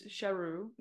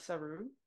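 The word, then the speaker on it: Sheru: And he's like, well, now this is my name, even though it was Sharu, not Saru.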